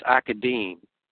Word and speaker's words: Academe: It's spelled A-C-A-D-E-M-E. Okay, that's pronounced Academe. Okay, I did not know that, but that's how you pronounce that, is Academe.